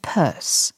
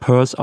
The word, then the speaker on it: Pierce: Präsident der Vereinigten Staaten und der einzige, der aus New Hampshire kam, muss natürlich nur deshalb purse ausgesprochen werden, damit er sich wohl oder übel auf worse reimt.